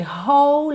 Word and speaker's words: whole: Whole, atenção à pronúncia dessa palavra, tá? Porque é bem diferente do que aparenta quando a gente lê. Então, ó, W-H-O-L-E, whole. Whole life, eu junto tudo aqui, porque é como se eu tivesse dois L's ligados, já que o E do whole não é pronunciado, tá?